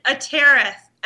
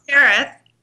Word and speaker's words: Atereth: How do you pronounce your last name? Am I pronouncing it right? – It's Atereth. – Atereth. – You know, I had a fabulous DJ tell me how everybody can remember it. Ready? – Uh-huh. – Atereth, like tearing up the charts.